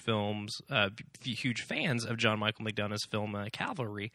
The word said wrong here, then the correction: Calvary: which is really hard to say without saying Calvary. – Well, you said it right the second time. – Oh, crap. Cavalry versus Calvary. – Cavalry are mounted horseback soldiers. Calvary is the hill on which Christ died.